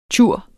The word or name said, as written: tjur